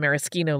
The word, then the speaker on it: maraschino: The original is 3/4 ounce of dry gin, 3/4 ounce of maraschino liqueur. Maraschino is the Italian pronunciation.